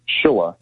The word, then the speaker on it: Schilla: Schilla.